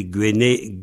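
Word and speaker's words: gwennelig: Et le diminutif de Guenel, c'est Guenelic. Guenelic. C'est-à-dire qu'on ajoute IG au bout. Et on prononce ic en qualité, ich, ich, etc. en vente.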